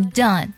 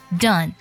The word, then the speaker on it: done: 第一个单词 done, D-O-N-E, 它的字母 O 呢发的是小口尖尖 a 的音。我们发音的时候呢，它是属于一种短促有力的状态，它呢一定不能拖音，它是的 done, done, 发这个小口尖尖的时候呢，上下是一根小拇指的宽度是最合适的。